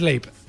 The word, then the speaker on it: Leipzig: Leipzig. – Ah, ¿Lo has dicho bien? – Sí, pero, como si dijeras, primero dices Leipzig. Leipzig. y luego le metes el zig.